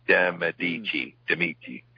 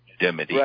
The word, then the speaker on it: de Medici: De Medici. There's an extra syllable in there, Bob. De Medici.